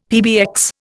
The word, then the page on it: PBX: Indian